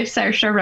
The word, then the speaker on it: Saoirse: Saoirse Ronan? – Saoirse. – Saoirse. – Saoirse. I even looked up a video on YouTube how to pronounce her name properly, so I got her to say Saoirse Ronan.